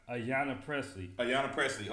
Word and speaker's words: Ayanna Pressley: Ayanna Pressley.